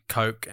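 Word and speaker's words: Koch: Bill Cock. – Bill Cock. – I didn't. In my head, I was like, don't say Cock. But how do we say it? – Bill Cock. – Coke. Coke.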